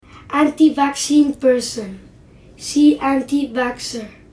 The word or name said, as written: anti-vaccine person